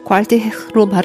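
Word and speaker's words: Gualtier: 이 구알테르 말데의 구알테르 발음을 놓고 설전이 있다고 하네요. 여기선 프랑스의 가난한 대학생이다 보니 구알테르로 발음하는 게 맞는 것 같아요.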